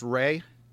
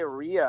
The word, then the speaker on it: Rea: So, Patrick, your last name is pronounced Ray. – Yes, correct. – Okay. Sean, I'm right for the first time. – Normally, first time you know, ever. It's funny. A lot of people say Rhea, and I'm like, well, I'm glad I didn't name my daughter Ghana, because that would have led to a lot of questions.